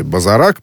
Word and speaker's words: Базарак: в столицу вот этой провинции Базарак. Правильное ударение ставлю, Даша? – Правильно, отлично. Абсолютно.